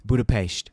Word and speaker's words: Budapest: Please pronounce it correctly. – Budapest.